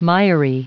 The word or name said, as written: miry